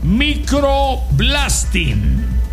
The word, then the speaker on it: microblading: Microblading. Oh, microblading. Así es que se pronuncia.